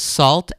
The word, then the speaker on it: Sault: I think the way you pronounce it is Salt, S-A-U-L-T is the band.